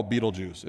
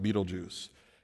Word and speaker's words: Betelgeuse: There's one that we call Betelgeuse. It isn't the proper pronunciation, but it's kind of what we call it, Betelgeuse.